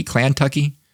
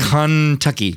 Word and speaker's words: Kentucky: Kentucky, Kentucky, Klantucky. – It's not Klant. – Oh no, it's not Klantucky. It's Kentucky.